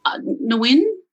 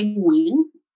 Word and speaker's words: Nguyen: Or yeah, or in Vietnamese you say Nguyen. – Nguyen. – Nguyen. Yeah. It's like a little Nguyen. So the G always tricks everyone. It's G is silent.